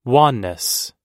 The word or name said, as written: wanness